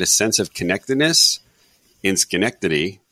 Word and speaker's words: Schenectady: You nailed it, Schenectady. You pronounced that properly.